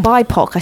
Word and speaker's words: BIPOC: 13 tales of terror and delight, each told through the lens of a biopic teen on a transformation Halloween night. Creepy, creative and unapologetically queer. – Mm-hmm. – BIPOC. I said that wrong. Sorry, guys. It was BIPOC.